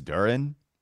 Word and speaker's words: Duren: I think it's Duren. So I don't know if you want to keep going Duren. Let's roll with that.